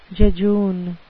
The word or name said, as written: jejune